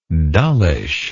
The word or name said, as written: dullishly